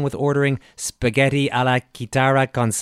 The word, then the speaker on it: chitarra: It's chitarra. It means guitar.